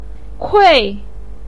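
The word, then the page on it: kui: tone 4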